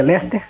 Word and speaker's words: Leicester: Teve 2-4-3 muito louco. Um entre Wolverhampton e Leicester, que pronuncia Leicester.